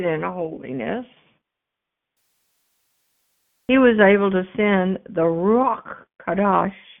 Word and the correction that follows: Ruach HaKodesh: Kadesh? How do you say it? – Ruach HaKodesh.